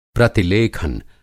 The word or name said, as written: प्रतिलेखन